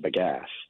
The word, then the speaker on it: bagasse: So how are you going to do this with, is it sugarcane or bagasse? Am I saying that right? – You are. Yeah.